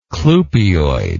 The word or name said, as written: clupeoid